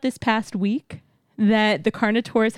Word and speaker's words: Carnotaurus: But on your left, on the wall up high, there is actually a second B mode Carnotaurus.